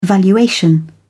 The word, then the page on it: valuation: British English